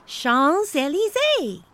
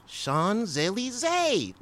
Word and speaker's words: Champs-Élysées: It's pronounced Champs-Élysées. – Champs-Élysées.